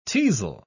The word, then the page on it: teazle: British English